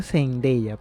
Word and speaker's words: Zendaya: según ella se pronuncia Zendaya, pero sí.